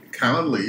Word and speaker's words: Calendly: I'm here today with the VP of Product from Calendly. And I even said that right. – Calendly, you got it right.